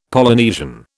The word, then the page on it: Polynesian: British English